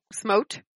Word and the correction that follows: Smount: It's weird. It's Irish. – Ah. – That's sort of how it's pronounced. – Smount. – Smount. It was one of those names I translated, and it looked really cool, and then I, like, got the pronunciation, and it's Smount.